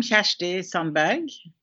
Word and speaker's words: Kjersti Sandberg: Yes, I'm Kjersti Sandberg, the founder.